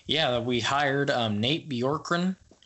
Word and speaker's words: Bjorkgren: I finally learned how to pronounce his last name. When I first heard it, I was like, how on earth? It's like Bjorgren or something like that. So I was like, how on earth do you pronounce it?